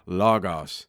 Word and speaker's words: logos: So this is an English translation word for the Greek word, the original word, logos, L-O-G-O-S, right? If you were to spell it in English. So logos, word, or logos, some people say.